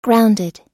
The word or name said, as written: grounded